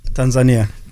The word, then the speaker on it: Tanzania: What, what's the right way to say it? Tanzania.